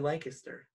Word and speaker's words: Lancaster: Deaners is such a good restaurant in Lancaster. – Oh my gosh. – You said it right. You said Lancaster.